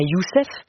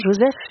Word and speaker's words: Joseph: Parfois, ils font même douter leurs interlocuteurs, ainsi de Mariam, qui demande à Joseph, son passager, Joseph, pas Youssef. Non, Joseph, il le prononce avec le O très ouvert et un peu long, ce prénom étranger mais articulé avec les voyelles de leur langue.